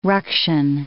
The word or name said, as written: ruction